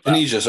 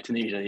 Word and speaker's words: Tunisia: Tunisia, sir. Tunisia. – Oh, sorry. Tunisia.